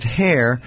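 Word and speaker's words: Heir: It's Dr. Gary, pronounces it Hair, but it's H-E-I-R, and he is a dentist, and he works at Newark Medical School and has a private office in Bayonne.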